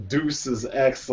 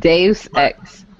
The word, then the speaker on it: Deus Ex: Like, do not ever call that game Deuces X like that. – Deus Ex.